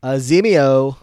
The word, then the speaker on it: Azimio: Say his name for me one time. – Azimio. – Oh my God, great.